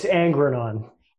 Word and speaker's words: Angrenon: It's Angrenon. – Angrenon. Troy Angrenon. – There we go. The second G is silent. So there, it's very easy.